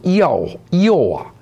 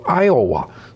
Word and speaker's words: Iowa: 这个英式读音读 Iowa，美式读音读 Iowa，所以呢现在我们的这个翻译呢还是跟美国，毕竟是美国的一个州，所以读爱尔华州啊，爱奥华州。